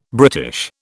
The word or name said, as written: British